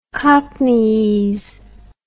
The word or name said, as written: cockneyese